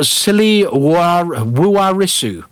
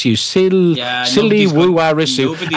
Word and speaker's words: Siliwarisu: But they have set up a silly Wuarisu. S-I-L-I-W-A-R-I-S-U. Silly Wuarisu.